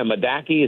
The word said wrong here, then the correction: Mordecai: is that how you pronounce it? I get it all confused. – Mordecai is how you pronounce it.